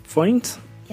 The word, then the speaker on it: Foynes: Yeah, is that how they pronounced it? Foynes? – Yes. – Because that actually is how it looks to be spelt, F-O-Y-N-E-S.